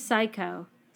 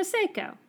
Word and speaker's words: Seiko: If it's S-A-I-K-O, I would say Psycho. If it's S-E-I-K-O, I would say Seiko. – It's E. You would say Seiko? I would. Okay, so Seiko.